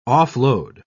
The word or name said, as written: off-load